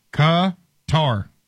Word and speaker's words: Qatar: The most common and accepted English pronunciation of whatever the country's name is Qatar. Qatar is the phonetics.